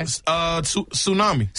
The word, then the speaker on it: tsunami: This has a silent letter in it. – Okay. – Tsunami.